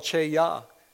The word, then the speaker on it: chayah: There's a Hebrew word called cheyah. It's spelled C-H-A-Y-A-H. Say cheyah.